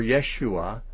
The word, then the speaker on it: Yeshua: Joshua, or Yeshua, a probably more authentic pronunciation, is a shortened form of Jehoshua, just kind of abbreviating it.